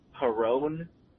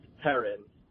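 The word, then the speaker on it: Perron: and you kept pronouncing it Perone. It's actually pronounced Perrin, and every time I heard you do it, I wanted to rip my ears out.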